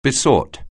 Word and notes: beseech: anglais

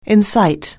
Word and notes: incite: anglais

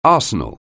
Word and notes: arsenal: anglais

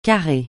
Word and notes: carrer: francais

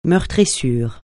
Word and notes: meurtrissure: francais

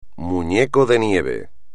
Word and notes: muñeco de nieve: espagnol

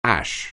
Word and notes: ash: anglais

